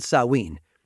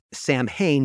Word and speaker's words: Samhain: It's really an amalgamation of different cultural traditions, but the roots stretch back to an ancient Celtic festival called Samhain, a word that, by the way, is often pronounced Samhain because of the way it's spelled, but you're getting it straight from the source here.